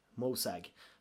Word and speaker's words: Mosag: Det är Aragog och hans fru Mosag. Mosag, vet inte hur man uttalar det. – Det lät bra.